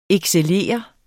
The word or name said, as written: excellere